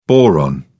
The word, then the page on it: boron: British English